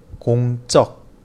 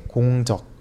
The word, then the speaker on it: 공적: 제가 이 내용을 가지고 온 이유는 발음 때문에 가지고 왔어요. 공, 그리고 적. 이렇게 되어 있지만 발음할 때에는 공적, 공적이라고 발음해야 해요. 그냥 공적이라고 하면 전혀 다른 의미가 돼요.